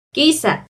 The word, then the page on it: quiza: Náhuatl